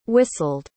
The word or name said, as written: whistled